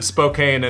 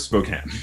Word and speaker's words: Spokane: And then pronounced it Spokane and he got beat up. – I do know that one. I do know it's Spokane.